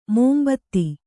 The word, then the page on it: ಮುಂಬೆದೆ: Kannada; mumbede